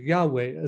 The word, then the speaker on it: Yahweh: Ah, pero usando los textos griegos, creemos que la pronunciación de YHVH pronunciará algo como Yahweh. Con lo que sabemos sobre cómo lo han traducido el hebreo hasta el griego, conociendo todo esto, eh, creemos que eh, Yahweh es cómo se pronuncia la YHVH.